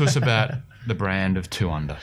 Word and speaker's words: 2UNDR: Well, look, essentially – Two Under, and the under is spelt UNDR, and it has a great logo.